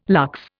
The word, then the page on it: lux: Indian